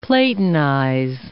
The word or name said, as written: Platonize